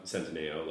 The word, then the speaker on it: Centineo: Centineo. – Centineo.